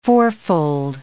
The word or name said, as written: fourfold